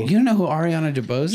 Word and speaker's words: DeBose: Ariana DeBose, right? I'm pronouncing that correctly? – Yeah.